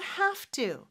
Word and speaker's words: have to: You have to. 자, 발음은 have 이지만 have, F 발음으로 해주시면 돼요. Have to, have to.